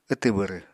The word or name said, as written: wtw bwrw